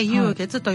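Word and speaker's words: 酉月: そうです、うん、っていうことは、うん、幽月、鳥月って書いて幽月と読むんですけど、はい、鳥っていうのは十二支のね干支の鳥年の鳥ですね、はい、に書いて幽月と読むんですけど、現在の暦で言うと 9 月のことですね。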